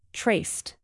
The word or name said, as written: traced